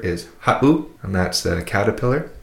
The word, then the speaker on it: ha7u: If you listen to that word ha'u, ha'u, it would be spelt H-A-7-U. If you were to take out that 7, it would be pronounced ha'u. But with the 7 in there, it makes that little glottal stop, that pause.